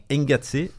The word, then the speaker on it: Engatsé: Engatsé, parce que en provençal, EN comme Ventavré, comme Barbet, on prononce E, donc Engatsé, ça descend de Incazzata,